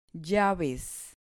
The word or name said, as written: llaves